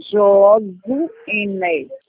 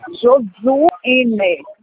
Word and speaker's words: Josuíne: Fala com o Josuíne. – Jesuíne, falei certo? – Hã? – Jesuíne, eu falei certo? – Não, Josuíne. – Josiene. – Josuíne.